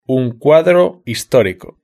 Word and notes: un cuadro historico: European Spanish

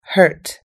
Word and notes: hurt: American English

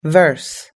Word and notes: verse: American English

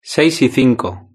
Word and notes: seis y cinco: European Spanish

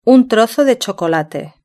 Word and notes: un trozo de chocolate: European Spanish

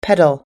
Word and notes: pedal: American English

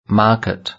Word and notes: market: British English

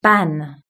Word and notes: ban: british-english

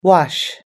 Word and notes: wash: us-english

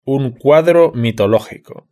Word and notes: un cuadro mitológico: European Spanish

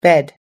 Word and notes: bed: American English

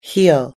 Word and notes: heel: American English

